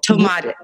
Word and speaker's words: Tomatis: Oh, Tomatis, that's what it's called. Tomatis. It's spelled T-O-M-A-T-I-S. Tomatis.